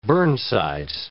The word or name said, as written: burnsides